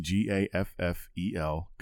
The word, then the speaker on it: Gaffel: So mine once again is the Gaffel, as I think how you pronounce it, G A F F E L, Gaffel Kolsch from Cologne, Germany.